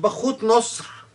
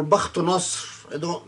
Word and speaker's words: Bakhtu Nasr: There is another story, this time from Persia, with Bakhut Nasr, or Bakhtu Nasr, that's how it's pronounced in the Arabic tradition, where he reportedly dug a pit and burned the followers of the prophet Daniel, who were Christian.